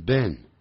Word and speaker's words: been: 它的这种句型很简单，用 have, has, have 或者是 has, 加 been, b-e-e-n, 不要念成 been 啊，要念成 been, been, it 的音啊，it 短音啊，短元音啊，been, 然后再放现在分词。如果你念 being, b-e-i-n-g, 要念 being 啊，说 the job。